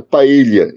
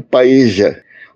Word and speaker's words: paella: Uma curiosidade: escrita com LL, palavra é pronunciada paella em espanhol. Argentinos pronunciam paeja. Os espanhóis odeiam essa pronúncia.